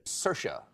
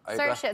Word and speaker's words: Saoirse: Saoirse. – Saoirse, it's perfect. Saoirse like inertia.